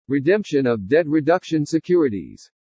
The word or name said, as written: REDEMPTION OF DEBT REDUCTION SECURITIES